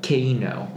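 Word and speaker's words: KEiiNO: It's called Spirit in the Sky by a band called, I forget how to like pronounce it, but it's Keino. It's K-E-I-I-N-O.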